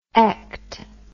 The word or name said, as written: ect